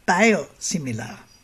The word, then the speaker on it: Biosimilar: Eigentlich spricht man auch das ein bisschen englisch aus, also Biosimilar. Aber wir können gern bei Biosimilar bleiben.